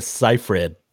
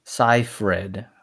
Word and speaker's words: Seyfried: Wait, how do you say her last name again? – Well, you know what? I looked it up before we recorded the podcast. It's Amanda Seyfried. – Seyfried.